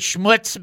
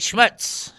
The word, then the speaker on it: schmutz: Some people pronounce it schmutz, but it's really like schmutz, schmutz. And you're thinking, what is that, right?